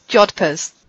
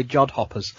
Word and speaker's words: jodhpurs: What did you just call the trousers? – Crap, how do you say it? – Jodhpurs. – That's what I said, I thought jodhpurs. – I heard you say jodhoppers.